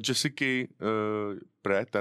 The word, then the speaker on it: Jessica Pratt: Písničku jsem vybral od Jessica Pratt, a nevím, jestli vyslovuji správně.